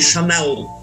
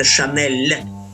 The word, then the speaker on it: Chanel: Во французском языке мягкое «л» читается. Господа американцы, это не Chanel, это Chanel.